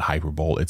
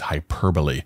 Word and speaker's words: hyperbole: It's not hyperbole, it's hyperbole. I wonder if Philip was using a little hyperbole here.